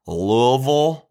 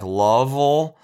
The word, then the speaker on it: Louisville: Our flight attendant, who I could tell we're not from there, said it Louisville. And that's kind of how I grew up knowing the city, Louisville. But then I also heard like people say, call it in like Louisville or like Lovell.